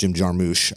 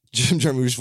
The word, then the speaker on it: Jarmusch: which I think it's pronounced Jim Jarmusch.